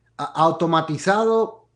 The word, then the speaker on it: Automatizado: Automatizado in the Dominican Republic. – Automatizado. – So it's like automation, but in Spanish, automatizado, to be automated.